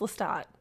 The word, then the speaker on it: Lestat: But I do think, after doing some minor sleuthing on the internet afterwards, that it should be pronounced Lestat. – Well, yeah, because it's Creole, it's French. – Right, and there's no, like, E after it. Okay, great. So we're all on the same page. It's Lestat, everyone.